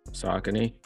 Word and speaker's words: Saucony: Saucony. – Saucony. Okay. So I never know how people say it. I hear some people say Saucony.